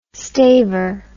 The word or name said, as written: staver